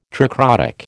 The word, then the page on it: tricrotic: American English